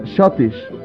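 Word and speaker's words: Schottisch: Diversas variantes del Scottish, Shottish, o sea, sí, se pronuncia Shottish, que quería decir como escocés.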